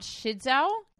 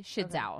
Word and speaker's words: Shidzue: Tell me what you know about Shidzo Kato. – Nothing. – Okay. So first of all, for my pronunciation, the way you spell her name is S-H-I-D-Z-U-E. Shidzo. Okay. – Shidzo.